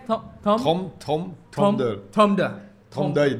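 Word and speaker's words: trompe-l'œil: How do you say it again? – Trompe d'œil. D'œil is eyes.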